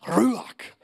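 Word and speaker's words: Ruach (רוח): If you want to say it right, like Jewish people do, you got to act like you got phlegm in your throat. It's like Ruach.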